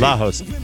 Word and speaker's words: Lagos: Smyrna, Delaware, and Lagos, Portugal. Lajos. It's a G, but they pronounce it H. – Crazy. – Lajos.